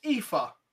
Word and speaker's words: Aoife: I'm sorry if I'm wrong, but in Irish, is that pronounced Aoife? – Yeah.